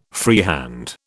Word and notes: freehand: British English

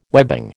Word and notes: webbing: American English

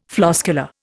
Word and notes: floscular: Indian

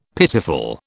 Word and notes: pitiful: British English

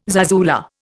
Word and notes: zarzuela: Indian

